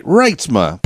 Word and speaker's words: Reitsma: Reitsma.